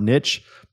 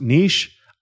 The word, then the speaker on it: niche: You know the whole lot, the word niche, probably best pronounced niche. I don't pronounce it niche. You want to know why? Because it doesn't rhyme with rich.